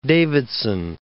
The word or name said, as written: Davidson